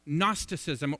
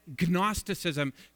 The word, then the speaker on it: Gnosticism: Gnosticism, or as the Brits say, Gnosticism, because you spell it with a G in the front.